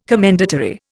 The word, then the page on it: commendatory: Indian